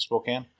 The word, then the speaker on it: Spokane: Lowe was last seen a day earlier in the East Sprague area of Spokane. – Is it Spokane? – Yeah, it's Spokane.